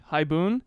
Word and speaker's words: haibun: So the word, there's a Japanese word called haibun. That is two syllables in English, haibun.